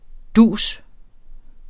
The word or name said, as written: dus